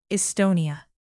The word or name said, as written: estonia